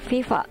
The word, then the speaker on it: FIFA: FIFA はフランス語での頭文字取った略で、フランス語の発音では FIFA となりますが、英語圏だと FIFA と呼ばれているそうですよ。